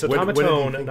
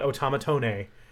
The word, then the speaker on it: automaton: And it's automaton, not automatone. – Right. – It ends with the n, not the ne, even though in the Roman letters it's spelled with an e at the end.